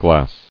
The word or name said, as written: Glassfuls